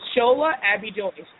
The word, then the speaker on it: Shola Abidoye: Sure, it's Shola Abidoye. – Oh, that's easy, just like it looks.